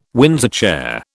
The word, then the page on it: Windsor chair: British English